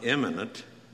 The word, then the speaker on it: immanent: Now, that is spelled I-M-M-A-N-E-N-T. It's not immanent, as if one is important or famous. It's immanent.